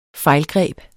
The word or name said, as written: fejlgreb